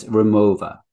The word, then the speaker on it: Rimowa: So the company, and I think you pronounce it with a V, because it's a German company from Cologne, and it's Rimowa.